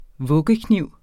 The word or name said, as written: vuggekniv